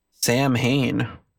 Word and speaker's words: Samhain: Yeah, well, I mean, it's if you look at the way that it's spelled and you don't know any, you think it's Samhain, but it's not pronounced that. – Samhain.